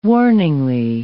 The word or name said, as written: warningly